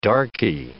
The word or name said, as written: darky